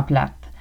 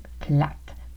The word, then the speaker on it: plate: Oui, il était vraiment plate. Il était plate. Donc on ne prononce pas plat, on prononce plate pour ennuyant.